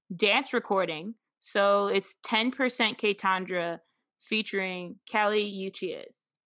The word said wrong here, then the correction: Uchis: I think I said that name wrong. – It's not, I always pronounce it just Kelly Uchis, but that could be all the way wrong. – Uchis. Is it Uchis? – Uchis.